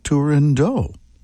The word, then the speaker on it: Turandot: A chamber orchestra fantasia on music from Giacomo Puccini's opera Turandot. Or do you say Turandot? Apparently, Puccini and Arturo Toscanini never pronounced the final T in Turandot.